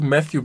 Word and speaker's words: Matthew: Ja, ist dir aufgefallen, dass du eben, als du meinst du Matthew Bellamy gesagt hast, meinst du mit dem TH ausgesprochen hast? – Nee.